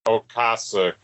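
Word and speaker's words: Ocasek: Is it Okasek or Okasek? – Okasek, I think. – See, I'm totally wrong.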